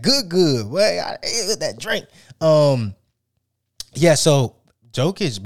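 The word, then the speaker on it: Jokić: I'm telling you, Jokic.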